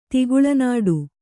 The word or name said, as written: ತಿಗುಳನಾಡು